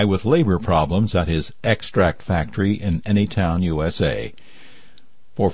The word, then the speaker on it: Extract: Extract. As you can hear, extract in this case is pronounced with the stress on the first syllable,